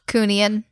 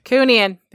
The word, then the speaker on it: Cooneen: Is that how you say it? Cooneen ghost? – Cooneen. – Cooneen. There we go.